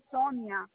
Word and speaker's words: Sonia: I said Sonia. – S-O-N-Y-A. – So, S-O-N-I-A.